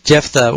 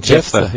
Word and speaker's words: Jephthah: Well, is that how you pronounce that name? – Yeah, Jephthah, well, at least in English.